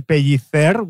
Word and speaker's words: Pellicer: It's a long story, my name, but if you pronounce it in Spanish, it would be Pellicer with the Spanish th, which Latin American people hate.